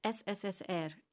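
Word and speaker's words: SSSR (СССР): Then, although the Soviet Union had ceased to exist five weeks earlier, she stamped the page with the letters CCCP, pronounced SSSR in Russian.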